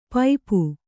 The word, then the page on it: ಪೈಪು: paipu